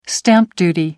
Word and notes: stamp duty: American English

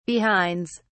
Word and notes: behinds: British English

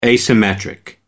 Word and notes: asymmetric: American English

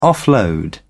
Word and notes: offload: British English

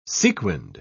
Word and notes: sequinned: British English

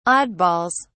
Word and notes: oddballs: British English

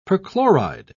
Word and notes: perchloride: British English

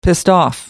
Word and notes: pissed off: American English

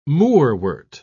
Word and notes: moorwort: British English

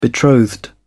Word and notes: betrothed: British English

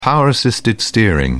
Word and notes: power-assisted steering: British English

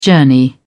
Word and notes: journey: British English

